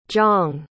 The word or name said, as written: jong